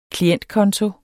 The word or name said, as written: klientkonto